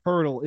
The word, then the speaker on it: Poeltl: So it is Jakob Poeltl is his name for the Raptors.